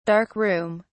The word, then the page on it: dark room: British English